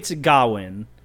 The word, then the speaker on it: Gawain: The Green Knight tells the story of Sir Gawain, which is crazy that it's pronounced that way to me. – Yeah, I'm gonna mispronounce it. So, Okay. So it's Gawain.